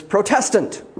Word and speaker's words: Protestant: Most folks who go to evangelical churches that call themselves Protestant, well, they even mispronounce the word Protestant. They don't recognize that it is Protestant. It is the church in protest.